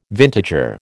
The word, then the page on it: vintager: American English